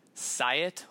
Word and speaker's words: Syatt: His name is Jordan Syatt.